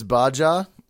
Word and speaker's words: Baja: I believe it's Baja.